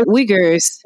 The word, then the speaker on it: Uyghurs: which has a Muslim minorities of people called Uyghurs? I hope I pronounced that properly. And so they were saying basically like they don't get cotton from this. – No, Uyghurs. – Uyghurs. – The Uyghurs.